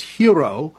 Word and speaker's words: gyro: And I have to point out, Hugh, a listener to the show told me how to pronounce gyro. It's hero.